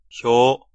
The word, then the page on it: facade: /fə.ˈsɑːd/